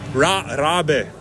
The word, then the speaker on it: Rabe: Radio Rabbit. – Rabe. – Rabe, how do you spell it? Rabe. R-A-B-E.